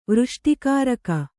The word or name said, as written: ವೃಷ್ಟಿಕಾರಕ